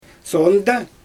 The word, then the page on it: soondæ: Xyzyl